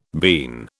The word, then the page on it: bean: British English